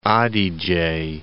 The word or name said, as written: Adige